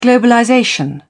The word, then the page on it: globalization: British English